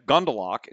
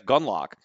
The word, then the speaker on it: Gundlach: You know, well, I need to apologize to Jeff Gundlach myself, because I didn't even realize that I had been mispronouncing his name on my last couple of podcasts. I think I was saying Jeff Gundlach instead of Jeff Gundlach. And, you know, I don't know why I did that, because, I mean, I've heard the guy's name many, many times, and so I know the way it's pronounced, but for some reason,